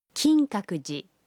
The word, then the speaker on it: 金閣寺: La prononciation est Kinkakuji.